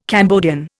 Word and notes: Cambodian: Indian

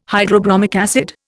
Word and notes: hydrobromic acid: Indian

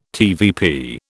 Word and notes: TVP: British English

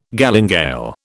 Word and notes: galingale: British English

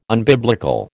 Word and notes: unbiblical: American English

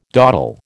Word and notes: dawdle: American English

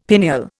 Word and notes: pineal: Indian

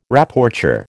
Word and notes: Rapporteur: American English

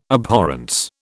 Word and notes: abhorrence: British English

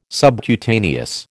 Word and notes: subcutaneous: American English

